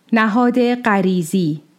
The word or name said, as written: Nahād